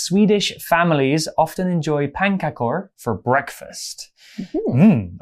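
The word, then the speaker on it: pannkakor: The first one is Sweden's pankakor. – Very good. Pankakor. That, that, that's how it's spelled anyway. – I mean, pankakor to me sounds like pancake.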